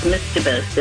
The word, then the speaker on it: mischievous: Yes, the proper way to say that is mischievous. If you say it Mike's way, you'll probably misspell it.